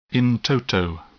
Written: in toto

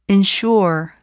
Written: ensure

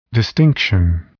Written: distinction

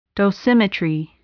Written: dosimetry

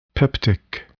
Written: peptic

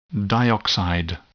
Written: dioxide

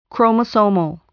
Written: chromosomal

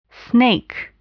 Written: snake

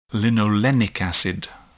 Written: linolenic acid

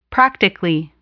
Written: practically

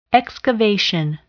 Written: excavation

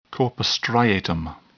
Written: corpus striatum

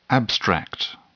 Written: abstract

